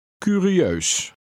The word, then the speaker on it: curieus: Read the I and EU combination separately. Remember the sound of the EU. – Curieus.